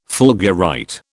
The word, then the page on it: fulgurite: British English